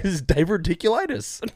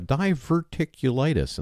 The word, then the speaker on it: diverticulitis: I've pronounced that wrong for most of my life. It's not really? Diverticulitis. – Oh, shit. – It's diverticulitis. – Did I say it right? – Because you said diverticulitis, which is the same way I've said it almost my whole life until I got the damn stuff.